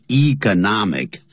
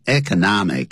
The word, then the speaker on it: economic: 有人念成 economic, 也有人念成 economic, 两个念法都可以。